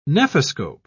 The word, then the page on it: nephoscope: British English